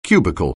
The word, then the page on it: cubicle: anglais